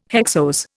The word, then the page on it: hexose: Indian